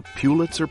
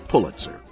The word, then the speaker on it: Pulitzer: By the way, you may have heard someone call it the Pulitzer Prize, but that is not the way Joseph pronounced his name. It is always correctly pronounced as Pulitzer.